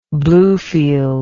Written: Bluefields

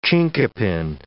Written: chinkapin

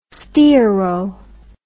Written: stearo

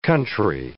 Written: country

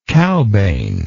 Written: cowbane

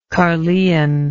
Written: Caerleon

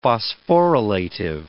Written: phosphorylative

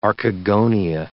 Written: archegonia

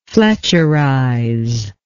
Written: fletcherize

